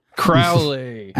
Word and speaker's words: Crowley: Rhymes with Owly. – Oh, Alistair, our boy Ally. – Crowley. – Ally yes. Crowley.